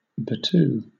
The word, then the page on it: battue: British English